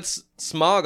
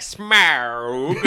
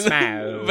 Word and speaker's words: Smaug: Well, first off, you said Smaug a little closer to how they say it in this movie, because in the other movies, they're like, Smaug! – It's Smaug.